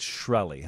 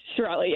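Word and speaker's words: Shkreli: It's Shirelli, huh? – Shirelli, yes. But everybody pronounces it differently, but yeah.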